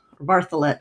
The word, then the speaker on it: Bartholet: I'm going to pronounce it Bartholet, I'm going to stick with that. I'm going to spell it for listeners. Elizabeth, B-A-R-T-H-O-L-E-T.